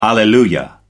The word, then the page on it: alleluia: American English